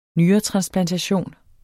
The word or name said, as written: nyretransplantation